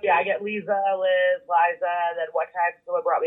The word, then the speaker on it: Liza: They pronounce it Lisa or Lisa, and whenever she gets her drink at Starbucks, she gets irritated with them. She's like, it's Liza. Like Liza with a Z.